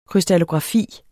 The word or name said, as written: krystallografi